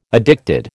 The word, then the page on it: addicted: American English